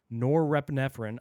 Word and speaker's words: norepinephrine: Using blood samples, the researchers showed that plasma, blood, dopamine concentrations increased 250% and plasma norepinephrine, I probably pronounced that wrong.